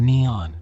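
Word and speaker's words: Nihon: The name for Japan in Japanese is written using the kanji and is pronounced Nippon or Neon.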